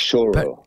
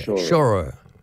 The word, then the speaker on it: choro: And the last time I saw you at Q on Hallett was you doing that coro style of Brazilian music. Is it coro? – Pronounced choro. – Yeah, choro.